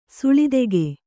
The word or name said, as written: ಸೆಳೆತೆಗೆ